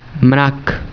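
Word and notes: awan: Malay

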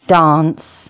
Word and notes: dance: English

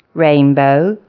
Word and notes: rainbow: English

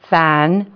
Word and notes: fan: English

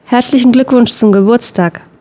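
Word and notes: ad gününüz mübarək: Azerbaijani

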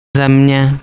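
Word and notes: ardhi: Swahili

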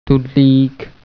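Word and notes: ciucio: Piedmontese

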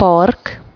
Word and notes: park: Hungarian

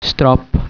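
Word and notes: sufit: Polish

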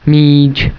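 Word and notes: bola: Malay

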